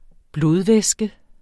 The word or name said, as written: blodvæske